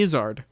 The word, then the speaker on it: Izzard: Eddie Izzard. – But it rhymes with wizard.